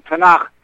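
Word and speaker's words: Tanakh (תנ״ך): If you look in Tanakh, as it pronounced properly, Tanakh, the Book of Kings and the Book of Kings, the story of King David and Solomon.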